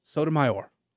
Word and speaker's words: Sotomayor: I think I've pronounced her name like three different ways. I'm sorry. Sotomayor.